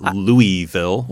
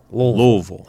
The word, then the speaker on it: Louisville: Way to pronounce the city right there. It's not Louisville. – Well, I've had enough experience. I mean, T4G has been there since 2006. And so Louisville.